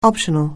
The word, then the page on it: optional: anglais